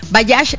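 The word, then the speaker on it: Bajaj: Bayash se escribe B de burro, A-J-A-J, ¿ok?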